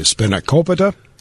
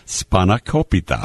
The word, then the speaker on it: spanakopita: Did I say it wrong? – Yes. – What is it? – Spanakopita. – That's what I said.